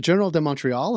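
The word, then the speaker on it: Journal de Montréal: Now, Quebec Telco Quebecor, which owns the Journal de Montreal and Journal de Quebec, good pronunciation there, thank you.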